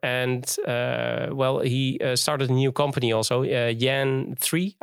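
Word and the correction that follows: JAN3: I don't know if I pronounce it good, right? – Jan. Yeah.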